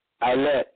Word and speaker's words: Eilat: They spell it E-I-L-A-T, but they pronounce it Eilat.